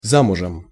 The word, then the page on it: zamuzem: Russian